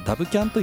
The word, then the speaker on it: WCAN: で、告知が開始されましたけども、 9 月 9 日の土曜日には名古屋でダブキャンというイベントですね、 WCAN ということで、ダブキャンと読むんですけども、こちらのイベントでこれからのウェブディレクターに求められること、 2023 年ウェブディレクターの棚卸しというイベントですね。